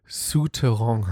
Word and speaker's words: Souterrain: Also ich bin ja auch gerade auf einer Suche äh, nach einer Wohnung und äh, ich, ich habe immer den, den, Namen immer falsch ausgesprochen. Ich meine, ich hatte auch bloß acht, nee, sieben Jahre Französisch, aber äh, mir hat eine Freundin neulich gesagt, dass das äh, Souterrain heißt.